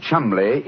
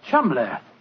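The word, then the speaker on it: Cholmondeley: Being an American, he had no reason to know that the name Chumley is in no way pronounced the way it is spelt. – But you, I never thought of that. Chumley. That name spelt C-H-O-L, Chul, M-O-N, Mon, D-E-Dur, L-E-Y.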